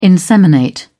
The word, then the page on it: inseminate: British English